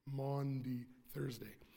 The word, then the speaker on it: Maundy Thursday: Maundy Thursday.